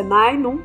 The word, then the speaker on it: Ninum: Também, outra curiosidade é que até agora eu tava lendo o nome do personagem do Dresser como Ninum. E agora eu descobri que a pronúncia é Nainum.